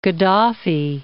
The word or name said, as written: Gadhafi